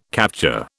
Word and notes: capture: British English

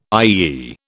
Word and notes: IEE: British English

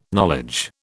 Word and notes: knowledge: British English

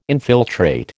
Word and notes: infiltrate: American English

